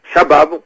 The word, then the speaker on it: Shabab: y compris la milice radicale Shabab ou Shebab, selon comment on le prononce.